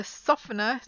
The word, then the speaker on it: softener: But the water softener, like some houses in areas with very hard water, they have to have a water softener. It's not a water softener, because that sounds strange.